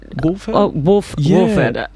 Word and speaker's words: Wolfhard: Finn, is it Wolfhard? Is that Wolford? – How you... Wolfhard. – Wolfhard. – There we go. Wolfhard. Well done on the pronunciation.